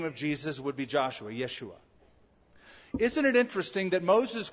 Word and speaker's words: Yeshua: The J is silent, sort of like Spanish. The Hebrew pronunciation of Joshua would be Yeshua.